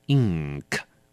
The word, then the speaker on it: ink: 再一次提醒您，遇到 G 或者是 K 前面的 N, 我们都要把它念成比较重的 N, 所以要念成 ink, 不要念错了。